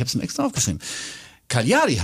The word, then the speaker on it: Cagliari: Das heißt ja nicht Calgary, weil man kommt von Calgary auf. Wie hast du es gerade gesagt? – Cagliari, ja. – Nee, ist es aber nicht.